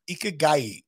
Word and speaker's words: ikigai: It's ikigai, which is a reason for being.